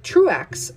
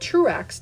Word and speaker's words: Truax: The hotel was not built on an empty lot, but a hotel, the four-story Truax, I don't know if I'm saying that right, Truax, T-R-U-A-X, the four-story Truax was already on the location.